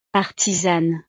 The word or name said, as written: artisane